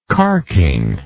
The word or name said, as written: carkingly